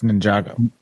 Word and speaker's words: Ninjago: That's Ninjago. Yeah. – Is it? – Yes. Ninjago. – Ninjago. – It's nin- it's pronounced Ninjago, but yes, it's just the words Ninjago.